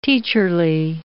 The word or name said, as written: teacherly